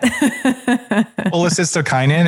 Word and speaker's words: cholecystokinin: Cholecystokinin.